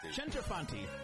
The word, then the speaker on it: Centofanti: But I have a wonderful guest, Jane Centofanti. Is that right? – Centofanti. – Yes. I gotta get the Italian. I gotta get the Italian pronunciation right.